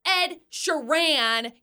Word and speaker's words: Ed Sheeran: I'm on air talking about Ed Sheeran, and he comes in and yells, "It's Ed Sheeran. You're saying it wrong."